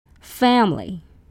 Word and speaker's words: family: F-A-M-I-L-Y. Your dictionary tells you the word has three syllables. But remember, the native speakers always go with the short one. They say family. Two syllables, not family. Three syllables.